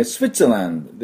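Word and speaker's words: Switzerland: ですから、でもスイスと言うとスイス人って意味になるから、スイッツランドっていうふうに言わないと、いけません、ね、通じません。でスイッツ、スイッツランド、ここのとこですね、 T、スペリングが TWITSZER、にランドです。はい、これ、 SW スで、スイッツランドでスイッツのとこ、 WI のとこにアクセントがあります。これだけ要注意です。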